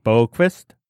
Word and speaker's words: Boqvist: I am taking Adam Boqvist. I said that wrong. Boqvist. No, that is right. Okay, That's right.